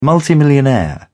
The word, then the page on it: multimillionaire: British English